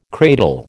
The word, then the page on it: cradle: American English